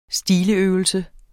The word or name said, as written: stiløvelse